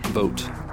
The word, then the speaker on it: Vogt: Oh, yeah, Vogt. V-O-G-T. But V-O-G-T. Like pronounced it like you're voting on something.